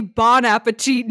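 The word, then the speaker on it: bon appétit: Well, yeah, because it's really bon appetit, not bone.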